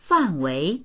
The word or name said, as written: fan4wei2